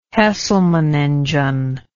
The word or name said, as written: hesselman engine